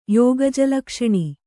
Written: ಯೋಗಜಲಕ್ಷಣಿ

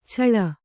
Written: ಛಳ

